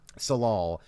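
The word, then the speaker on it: Tsalal: They're basically, they're called in to figure out what happened to the scientists at the local Tassal, is that how you pronounce it? – Salal.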